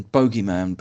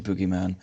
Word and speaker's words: bogeyman: It was Boogeyman from 2005, as in Bogeyman, but the Americans say Boogeyman.